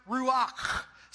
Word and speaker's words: ruach: It was a Hebrew word, and it's pronounced ruach. So turn to your neighbor, spit on their face, and say ruach, okay?